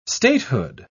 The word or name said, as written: statehood